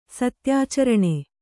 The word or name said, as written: ಸತ್ಯಾಚರಣೆ